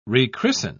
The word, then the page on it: rechristen: British English